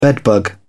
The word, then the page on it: bedbug: British English